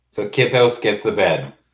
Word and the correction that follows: Kithnos: Kithnos, right? Kith, like with an E kind of? – He actually spelt it on his character name now. – Kithnos. – Kithnos. – That's like phonetically how you say it.